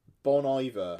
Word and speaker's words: Bon Iver: Is it Bon Iver? Is that how you pronounce it? Bon Iver. – Bon Iver. – Right, it's kind of how it's spelt, but yeah.